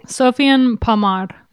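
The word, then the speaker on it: Sofiane Pamart: Sofiane Pamar. There's a T at the end because he's French, and the T is silent because French.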